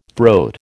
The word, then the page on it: throat: American English